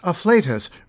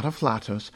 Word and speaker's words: afflatus: And there is afflatus, related to inflate and flatulent, and to be pronounced with the same long A, not afflatus, but nearly always seen with divine before it.